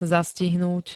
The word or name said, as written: zastihnúť